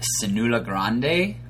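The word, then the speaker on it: Siula Grande: Cenoula Grande, I believe is how you say it. – That's how I say it.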